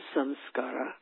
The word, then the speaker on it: samskara: One is samskara, which, as has been pointed out, often rhymes with some scars, and that is what they are.